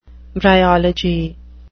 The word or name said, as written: bryology